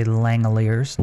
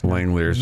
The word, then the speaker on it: Langeliers: Yeah, just say Langeliers. – Langeliers, okay. – Yeah, the G is silent. Okay. Langeliers, yeah.